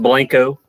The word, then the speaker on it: Blanco: Yeah, it's pronounced Blanco. – Blanco. That's the Kentucky and me calling it, pronouncing it wrong.